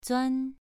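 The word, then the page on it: zuan: tone 2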